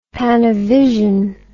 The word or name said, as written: Panavision